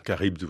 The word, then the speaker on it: Charybde: Charybde, oui. – Oui, Charybde. – Et pas syllabe. – Non, mais vous avez bien dit, c'est moi qui entendais syllabe, parce que vous voyez, la langue.